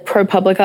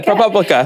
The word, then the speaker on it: ProPublica: Um, I actually I got sent the pilot script and the um, the ProPublica article. Mm-hmm. Um, I'm saying that wrong, aren't I? Um, you said it right, girl. It right. You're good. ProPublica.